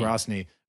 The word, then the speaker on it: Grozny: Grozny. Maybe, do Americans say Grozny? – We say Grozny. – In Russian, it's Grozny. Grozny. Grozny. So Grozny.